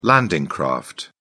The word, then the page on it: landing craft: British English